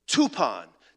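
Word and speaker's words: tupon (τύπον): It rhymes with a word we're used to in our English language. The Greek word, for example, is tupon, T-U-P-O-N, English spelling. Tupon rhymes with coupon.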